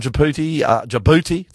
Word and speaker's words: Djibouti: If you're from Djibouti, did I say that right, you reckon, mate? – Yeah, I reckon.